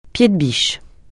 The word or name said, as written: pied-de-biche